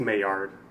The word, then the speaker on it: Maillard: I think it's Maillard. – Is it Maillard? – I think so. – Oh, thank you. I've been pronouncing it wrong for fuck ever. So the 2 L's is pronounced as a Y? – I think it's, like, French or something else.